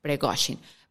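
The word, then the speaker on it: Prigozhin: Primero tenemos que saber quién es Pregoshin, porque le estuve viendo muchos podcasts para entender cómo se pronunciaba y aparentemente es Pregoshin.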